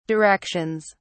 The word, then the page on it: directions: British English